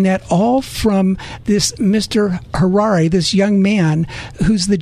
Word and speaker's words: Harari: His name is Noah Yuval Harari. It's almost like Ferrari. It's spelled H-A-R-A-R-I.